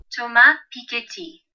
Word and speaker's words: Piketty: So the book is called Capital in the 21st Century, and it's by a French economist whose name I looked up how to pronounce on Google, and I am pleased to report it is Thomas Piketty.